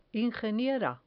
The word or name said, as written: ingeniera